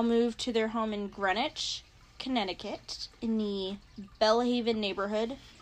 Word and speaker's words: Greenwich: I honestly am glad I watched a documentary on this case because the town is called Greenwich, but it's spelled Greenwich.